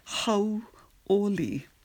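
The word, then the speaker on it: how oli (hauʻoli): H-A-U, that's the first syllable, H-A-U. How, like you're saying H-O-W, but you make a oo sound on the N. So H-A-U is how, and then the next syllable is O, regular O, and then the last syllable is li, L-I. So it's H-A-U, how, O, that's the O sound, li, L-I, how oli, how oli.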